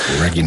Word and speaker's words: reggae: Escolto cada matí el món i observo que insistiu en dir rigui a l'estil musical jamaicà conegut mundialment com reggae. Pronunciat reggae arreu.